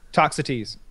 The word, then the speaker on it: Toxotes: But so Sour Patch Adults wrote, with all that wood, you should really start pumping out some Toxotes. Did I pronounce it right? – Toxotes. – Toxotes. Okay.